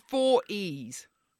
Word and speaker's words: E444E: It's four E's, like in the notes E's. That our music producer, our music director, Steph, she yes. has little notes kind of in here sometimes of how you pronounce things. And in brackets, she has pronounced four E's.